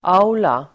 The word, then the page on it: aula: ['a_ola]